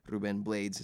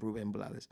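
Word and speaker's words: Rubén Blades: Ruben Blades is actually how it's pronounced, but most Latin Americans know him as Ruben Blades.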